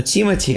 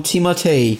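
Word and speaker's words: Timothée: То есть, если говорить по- по-английски, то это будет, ну, Тимати, а если будет если мы говорим по-французски, то он Тимотей.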